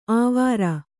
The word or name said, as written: ಆವಾರ